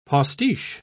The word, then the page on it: postiche: British English